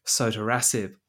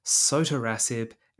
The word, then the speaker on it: sotorasib: Well, I've heard it both ways, but I think the people who have lots of Twitter followers and lots of publications tend to pronounce it sotorasib.